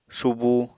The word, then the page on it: subu: Apatani